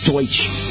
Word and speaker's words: Deutsch: And actually, one good guy who probably just mispronounced Donnie Deutsch's name. It's Deutsch. But I understand why you'd have a tendency to mispronounce it.